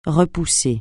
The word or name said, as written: repousser